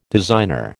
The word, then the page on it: designer: American English